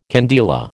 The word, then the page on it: candela: American English